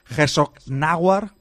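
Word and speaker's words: Herzogenaurach: en un pueblo que se llama Herzognaurach, así se pronuncia.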